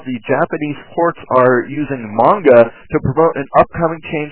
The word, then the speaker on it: manga: But one thing that really annoys me is on some other anime-related programs, on TV or more likely the internet, when they do not pronounce manga as manga, they pronounce it as manga. It is not manga, because that is not how Japanese is pronounced. It's manga, okay? Manga, all right? Not manga. So get it straight, pronounce it right.